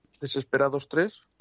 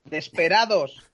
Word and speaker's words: Desperados: Desesperados 3. – Desperados.